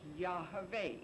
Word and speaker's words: Yahweh: And it's pronounced Yahweh. It is not Jehovah. It is not Yahweh. That's incorrect. Now, that's the correct way.